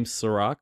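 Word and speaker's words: Serak: Just type in the Weekly Review, or you can type in my name, Chirag, which is S-E-R-A-K. I know, unfortunately, not spelled like the liquor, but I promise you, I taste way better.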